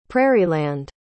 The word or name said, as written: prairieland